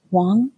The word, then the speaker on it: Huang: But um, I recently read Unnatural Ends by Christopher Wong, I believe is how you say it. The last name is spelled H U A N G.